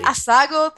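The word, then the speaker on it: Assago: That Asago thing you thought you were mispronouncing and you were pronouncing exactly right.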